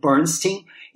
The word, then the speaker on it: Elmer Bernstein: E tanto que, para diferenciar no mundo musical, o Elmer que, o, cujo nome a gente pronuncia Bernstein, ele era conhecido como Bernstein do Oeste, por causa de Hollywood.